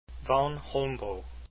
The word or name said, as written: Holmboe